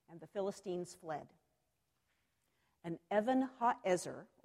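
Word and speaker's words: Evan ha'ezer (אבן העזר): The word Ebenezer is actually two Hebrew words pronounced together. Evan ha'ezer.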